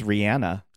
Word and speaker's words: Rihanna: It's Rihanna. – Oh. – Yeah. – That sounds like me with like vocal fry though. – Yeah. – Rihanna.